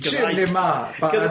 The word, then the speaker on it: Cinema Paradiso: Cinema Paradiso.